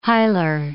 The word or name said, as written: hilar